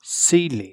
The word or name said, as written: ceiling